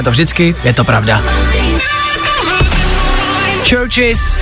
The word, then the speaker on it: CHVRCHES: Tohle jsou churches, když to budete hledat, je to jako víc kostelů, ale místo U je V, takže ch, v, r, ch, s, chvrches, chvrches se to píše, chvrches, ale čte se to churches.